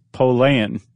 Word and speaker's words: Pollan: Do you know how to pronounce his name correctly? – No. – Pollan.